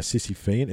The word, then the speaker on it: Sisyphean: How do you spell it? – S-I-S-Y-P-H-E-A-N. Okay, a Sisyphean. It's a Greek word, so you got to think about that word. Sisyphean.